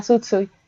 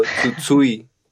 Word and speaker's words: Tsutsui: que es Yasutaka Tsutsui, eh, pues Ay, qué bonito lo pronunciaste. Yo, Tsutsui. Tsutsui.